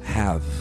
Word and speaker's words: have: O exemplo clássico é o verbo ter. O verbo ter em inglês, que termina com E, mas se pronuncia have. Você termina a pronúncia na letra V, hein?